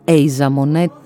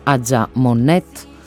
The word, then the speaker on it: Aja Monet: Κάποιοι την προφέρουν Asia Monet, Αμερικανίδα ναι, δεν αντιλέγω. Εμένα μου βγαίνει το Aja Monet, είμαι του Cambridge και όχι του Michigan.